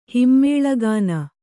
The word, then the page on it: ಹಿಮ್ಮೇಳಗಾನ: Kannada